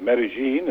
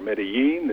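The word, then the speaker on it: Medellín: I'm in the city of Medellin, as it's pronounced here, or Medellin in other Spanish languages in Colombia.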